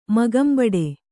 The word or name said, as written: ಮೊಗಂಬಡೆ